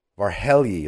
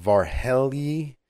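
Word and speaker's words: Várhelyi: European Commissioner for Neighborhood and Enlargement, Oliver Varhelyi, if I'm pronouncing that correctly, told Foreign Minister Eli Cohen in Brussels today, this morning.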